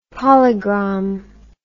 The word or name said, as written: polygram